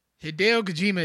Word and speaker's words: Hideo Kojima: He's being Hideo Kojima is, did I say his name right? – Yeah.